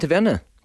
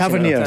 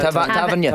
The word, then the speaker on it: Tavernier: Do you think it'll be interesting, Tavernier? How do you say his name? Sorry, I keep Tavernier. – Tavernier.